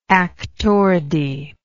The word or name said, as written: actoridae